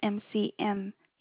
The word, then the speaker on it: McMurray: Okay, you say your last name, MCURRAY, and what about your first name? – No, MCMURRAY. – Oh, I'm sorry, so that's MCMURRAY.